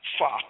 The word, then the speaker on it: Foxx: words Trader, T-R-A-D-E-R, Fox, and Fox is spelled F-O-X-X.